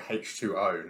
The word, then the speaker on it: H2O: Everyone calls it H2O, not H20, because the Americans say O instead of zero.